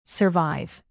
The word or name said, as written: survive